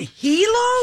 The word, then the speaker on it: Hilo: H-I-L-O is the island. – Oh, Hilo? It's in Hilo. They're in Hilo? – Hilo, Hawaii. Yes, that's where they're filming this one. – Okay. – Now, Grand Nana Loa Hotel in Hilo.